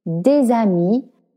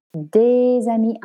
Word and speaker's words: des amis: Des amis intimes, D-E-S-A-M-I-S. Two words, but it sounds like one because I'm making the liaison. As two separate words, they're pronounced des amis, but together, how we speak in French, des amis, right?